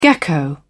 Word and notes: gecko: British English

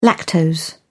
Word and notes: lactose: British English

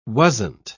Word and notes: wasn't: British English

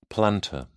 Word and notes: plantar: British English

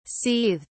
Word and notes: seethed: British English

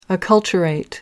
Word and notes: acculturate: American English

